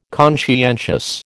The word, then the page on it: conscientious: American English